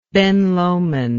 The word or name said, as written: Ben Lomond